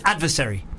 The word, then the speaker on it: adversary: Do you know what I say wrong all the time? And I don't give a fuck. I'm going to continue to say it wrong. Adversary. Everyone says adversary. – Adversary is the right way. I say adversary. – You look up the definition and the phonetics of adversary anyway, and they'll all say adversary.